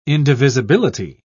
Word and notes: indivisibility: British English

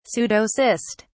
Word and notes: pseudocyst: British English

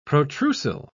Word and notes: protrusile: British English

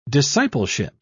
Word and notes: discipleship: British English

